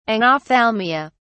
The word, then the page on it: anophthalmia: British English